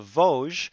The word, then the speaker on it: Vosges: which is the Battle of Vosges. And I know how to pronounce that because there's a chocolate so named.